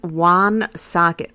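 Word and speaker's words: Woonsocket: it's pronounced Wan Socket.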